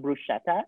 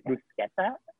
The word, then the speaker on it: bruschetta: It's not bruschetta, it's bruschetta.